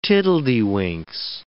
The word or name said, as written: tiddledywinks